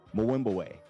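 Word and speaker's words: Mbube: It's M-B-U-B-E. But Mwimbawe is how they say it.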